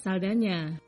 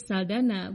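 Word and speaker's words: Saldaña: Dalam bahasa Spanyol yang umum di Amerika Latin, huruf N dengan tanda berliku diucapkan seperti nye. Karena itu Saldana dengan huruf N yang disertai tanda berliku seharusnya dibaca Saldanya. Sayangnya, Hollywood lebih mengenal artis ini sebagai Saldana, bukan Saldanya.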